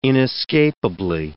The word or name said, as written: inescapably